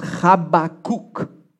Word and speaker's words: Habakkuk: The way you pronounce this in Hebrew is Habakkuk. Okay, you try that with me. Habakkuk. Yeah, you got to do the ch in the beginning, right? So just he's the kook, okay?